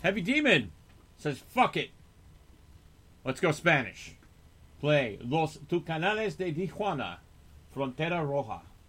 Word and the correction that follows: Los Tucanes de Tijuana: Los Tucanes de Tijuana.